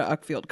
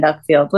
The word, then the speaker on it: Uckfield: What are people majoring in Uckfield? What's their specialty? I hope it's an art school. – Like yeah, it's spelled like Fuckfield, but without the F at the beginning.